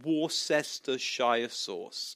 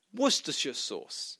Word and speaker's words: Worcestershire: I said, "Excuse me, not to be rude or anything, but it's not Worcestershire sauce. It's actually pronounced Worcestershire sauce." And he said, No, it's not. No, it's not. It's pronounced Worcestershire sauce. How many syllables he's using, I have no idea. But it was like a mouthful just to say it. And I said, no, it's not. It's Worcestershire sauce.